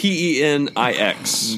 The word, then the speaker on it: Penix: sure we are pronouncing that. P E N I X. Junior. – Penix. The junior helps.